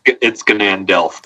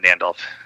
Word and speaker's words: Ganandelf: Yeah, it's Ganandelf. The Ganandelf. Yeah, Ganandelf. I like Ganandelf better. Than Nandelf. It's not a silent G.